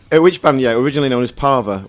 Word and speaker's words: Parva: Parva. – Parva, how are you spelling that? – I spell it P-A-R-V-A. – Or like Palmer, but with a V.